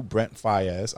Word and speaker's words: Brent Faiyaz: The new Brent Faiyaz.